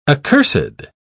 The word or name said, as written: accursed